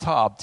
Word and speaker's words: Tob: It's pronounced Tob, T-O-A-B in pronunciation.